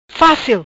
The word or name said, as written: Facil